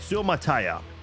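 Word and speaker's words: Suamataia: That is how you say it, Suamataya. Had to look that up a couple of times, but overall a very good pass blocking offensive tackle here.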